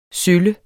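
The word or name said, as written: SØLLE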